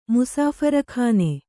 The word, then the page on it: ಮುಸಾಫರ್‍ಖಾನೆ: kannada